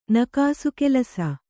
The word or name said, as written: ನಕಾಸೆಕೆಲಸ